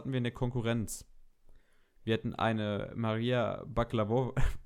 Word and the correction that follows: Bakalova: Bakalova. Es tut mir leid, dass ich den Namen so beschissen ausspreche die hat die Tochter von Burat gespielt im zweiten burat film Und ich fand sie sogar echt stark.